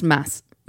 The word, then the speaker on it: Mads: I just looked it up. It's pronounced Mass. Christmas. – What? – It says it's pronounced M-A-S, like in Christmas. – Mass? – Oh. – Yeah, so like Mass. – So the Mads D is Mikkelsen. Silent.